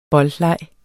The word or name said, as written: boldleg